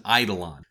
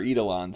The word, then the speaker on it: Eidolon: It's Eidolon. Eidolon. That's how you say it. – Yeah, I say Eidolon or Eidolon, so you can huff my shorts. – No, according to Greek literature, it's Eidolon. That's how you say the word.